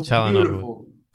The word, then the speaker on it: Çalhanoğlu: So I'm Çalhanoğlu. Oh, there you go. Çalhanoğlu.